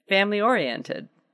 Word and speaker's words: oriented: Orientated? – Yeah, I know that I'm saying it wrong. – Where are you going? Family. – Orientated. Oriented. Oriental? – Okay, you're trying to make it too fancy. Family-oriented.